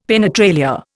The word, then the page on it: penetralia: Indian